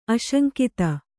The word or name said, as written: ಆಶಂಕಿತ